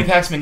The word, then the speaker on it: Paxman: Paxman? I can never pronounce his name properly. – Oh, Jeremy Paxman. – Paxman, yeah.